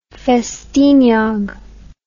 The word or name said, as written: Ffestiniog